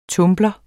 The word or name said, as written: TUMBLERE